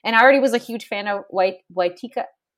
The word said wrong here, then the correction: Waititi: Taika Waititi. I'm so sorry. I feel so terrible. I'm terrible pronouncing his name.